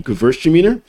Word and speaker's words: Gewürztraminer: Gewürztraminer, the W is always pronounced as a V, Gewürztraminer.